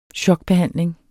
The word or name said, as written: chokbehandling